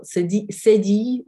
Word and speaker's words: cédille: It's spelled with a C with a cédille, or cédille, it is in French, or cédilla. I want to say cédilla, or it's kind of Spanish.